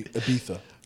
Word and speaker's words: Ibiza: That's Ibiza. – And if we're pronouncing it natively, Ibiza.